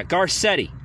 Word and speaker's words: Garcetti: Is that how you say his name, Josh? Yeah, Garcetti, right? Literally.